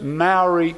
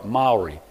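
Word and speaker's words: Māori: New Zealand's first Maori politician. I didn't say that right. Maori, my New Zealand friends.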